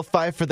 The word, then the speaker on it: Pfeiffer: It's Michelle Pfeiffer.